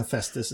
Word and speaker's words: Hephaestus: Is it Hephaestus? Is that how we pronounce that correctly? Hephaestus.